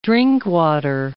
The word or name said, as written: Drinkwater